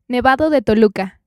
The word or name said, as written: Nevado de Toluca